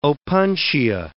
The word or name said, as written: opuntia